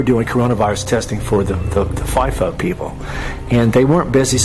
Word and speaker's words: FIFO: I call it FIFO, but some people might pronounce it FIFO, F-I-F-O.